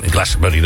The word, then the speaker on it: Glastonbury: Dat vindt altijd in Glastonbury natuurlijk plaats.